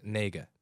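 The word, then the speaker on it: nega (那个): In Mandarin Chinese, there is a phrase that's pronounced nega, nega.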